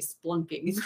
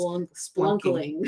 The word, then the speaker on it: spelunking: And then they started talking about, was supposed to be spelunking. – I was going to say, whatever they were saying was mispronounced, yes. – Spelunkling.